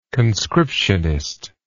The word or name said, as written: conscriptionist